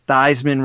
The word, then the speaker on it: Theismann: You may have noticed that Drew mentioned Joe Theismann went by Theismann at the time. And that was true before Theismann went to Notre Dame, where Theismann rhymes with Heisman, became a catchphrase.